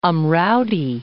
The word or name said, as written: Amraoti